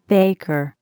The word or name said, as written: baker